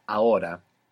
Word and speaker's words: ahora: This is spelled A-H-O-R-A. A-H-O-R-A. Ahora. – Ahora. – Good. – Is the H silent in every... – Yeah, unless it's C-H, in which case it's like English, ch.